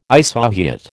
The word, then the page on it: isohyet: American English